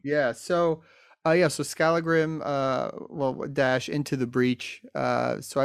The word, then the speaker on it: Skallagrim: Skallagrim. That's the way I say it.